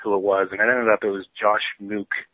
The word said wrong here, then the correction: Mickey: Mickey. Josh Mickey.